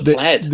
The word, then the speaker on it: plaid: Oh, the plaid. – The plaid. Is that how you say it? Plaid. – Like the plaid clothing.